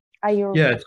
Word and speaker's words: Ayurveda: How do you say it? Is it like Ayurveda? – Ayurveda. – For me, it's like a tongue twister. Ayurveda. – Ayurveda. No, that's good. – Ayurveda. Okay. Perfect.